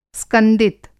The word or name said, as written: स्कंदित